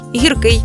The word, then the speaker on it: гіркий: До речі, у більшості двоскладових прикметників наголос падає на закінчення. Новий, старий, гіркий, тонкий, товстий, тісний, вузький тощо.